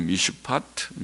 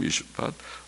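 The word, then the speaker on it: 미슈파트 (מִשְׁפָּט): 원문에는 공평과 포악, 공평이 미슈파트, 히브리 말은 미슈파트, 포악이라는 말은 미스파크, 발음이 비슷하죠. 미슈파트, 미스파크, 또 의로움이란 말은 체다카, 그리고 부르짖음은 체아카. 하나님께서는 비슷한 발음의 두 단어를 사용하시면서 대조하십니다.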